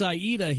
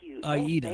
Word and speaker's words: Aida: Aida or Aida. I can't remember how to pronounce it. – It's Aida. He rewrote the opera. Aida.